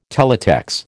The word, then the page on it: Teletex: American English